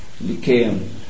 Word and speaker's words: Lyceum: or probably pronounced it Lyceum. I think the Greeks, that C would be pronounced as a K, Lyceum.